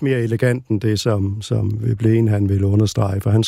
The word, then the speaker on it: Veblen: Det er Veblen.